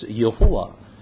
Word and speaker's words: Yehovah: and it is being pronounced as Jehovah. Or if I pronounce it from Hebrew way, it must be said as Yehovah.